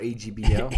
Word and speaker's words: AGBO: Netflix continues to spend more money as they partner up with the Russo Brothers production company, Agobo? – Or AGBO. – AGBO.